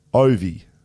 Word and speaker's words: Ovi: And Ovi is pronounced Ovi. – There you go. – Not Ovi.